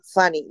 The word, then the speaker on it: FVNIY: Se escribe FVNIY. Fanny, la pueden encontrar en las plataformas de música también.